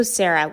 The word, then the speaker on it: Sarah: My name is also Sarah with an H, the only true way to spell it.